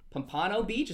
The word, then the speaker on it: Pompano Beach: Pompano Beach, is that how you say it? – Yep.